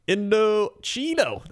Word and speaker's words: Indochino: Indochino. That's right. Indochino. That's how you say it.